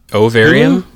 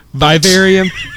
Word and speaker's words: Vivarium: And then also Vivarium on Ovarium? – No, not Ovarium. Vivarium.